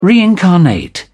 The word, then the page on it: reincarnate: British English